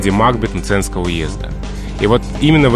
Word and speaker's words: Макбет: то мы должны говорить «Макбет».